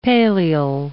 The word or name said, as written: paleal